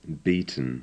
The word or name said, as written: beaten